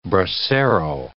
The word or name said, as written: bracero